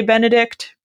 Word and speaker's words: Benedict: You know, you might not hit that T really hard when you say Benedict, but it's definitely there.